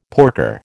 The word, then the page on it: porker: American English